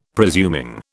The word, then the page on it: presuming: British English